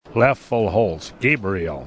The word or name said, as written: Loeffelholz